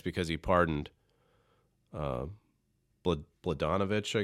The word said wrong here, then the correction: Blagojevich: I knew I'd forget how to say it. Blagojevich, I believe that's how you say it.